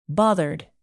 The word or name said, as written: bothered